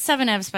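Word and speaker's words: Seveneves: Is it Seven Evs or Seven Eves? – It's pronounced Seven Evs by Neal Stephenson, but it's spelled Seven Eves, all one word.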